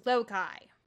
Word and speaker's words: Lokai: Lokai is spelled like Loki, but it's pronounced Lokai.